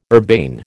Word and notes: urbane: American English

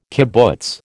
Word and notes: kibbutz: American English

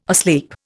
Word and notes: asleep: Indian

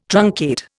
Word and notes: truncate: Indian

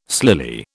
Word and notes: slily: British English

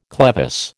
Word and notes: clevis: American English

